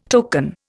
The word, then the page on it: token: Indian